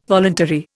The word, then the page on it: voluntary: Indian